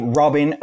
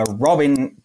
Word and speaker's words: Robin: And I gave Robin a Robin pronunciation instead of Robin, som jag skulle säga, as I would have said in Swedish.